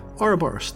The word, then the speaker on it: Ouroboros: Yeah, Ouroboros, the snake eating its tail.